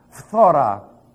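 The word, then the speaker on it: phthora (φθορά): P-H-T-H-O-R-A. It's like P-H-T-H. That's... No, it really isn't. Listen to it. It's phthora. Okay? I know you're going to have difficulty with it, but you're not Greek. Phthora. You're going to go phthora. And then you have to roll the R.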